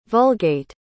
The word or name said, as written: Vulgate